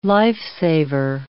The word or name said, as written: lifesaver